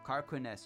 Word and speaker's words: Carquinez: Carquinez Strait. C-A-R-Q-U-I-N-E-Z. Strait.